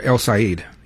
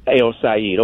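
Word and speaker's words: El-Sayed: Now, as far as Mike Rogers and El Sayed, is that how you pronounce his name? – Yeah, El Sayed. – El Sayed.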